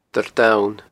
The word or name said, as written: Doire Donn